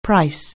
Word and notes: price: anglais

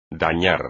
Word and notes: dañar: espagnol

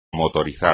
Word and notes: motorizar: espagnol

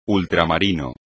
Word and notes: ultramarino: espagnol

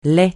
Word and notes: lait: francais

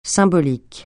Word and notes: symbolique: francais

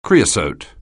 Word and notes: creosote: anglais

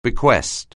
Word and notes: bequest: anglais

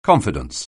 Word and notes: confidence: anglais

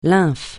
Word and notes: lymphe: francais

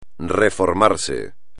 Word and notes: reformarse: espagnol

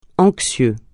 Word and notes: anxieux: francais

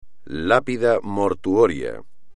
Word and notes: lápida mortuoria: Spanish

